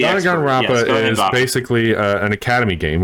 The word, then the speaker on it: Danganronpa: If you like Japanese games, though, Danganronpa. That's how you pronounce it. Danganronpa.